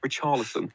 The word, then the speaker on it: Richarlison: Richarlison, for me, is the talent. I don't know why I said it like that. Richarlison? Richarlison is the talisman. I've pronounced it like he's some Serie A player, but Richarlison is their talisman.